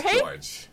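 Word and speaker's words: Georges: And how do you say his name? George's? – I think it's Jorge? – Just George. – George. Just George. Okay.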